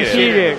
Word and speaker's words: Shearer: igualando a Shearer, con 7 goles ambos. – ¿Ha dicho Shearer? – Es que no sé pronunciarlo muy bien, la verdad. – Alan Shearer.